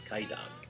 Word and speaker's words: Kwaidan: But it's K-W-A-I-D-A-N, but that's pronounced Kaidan.